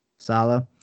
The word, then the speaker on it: Saleh: Saleh, I think that's how you say it, right? Saleh? – Sala.